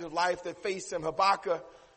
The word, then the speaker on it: Habakkuk: Just like you and I, again, in 626 B.C., there was a prophet by the name of Habakkuk. Some pronounce his name Habakkuk, as either is fine.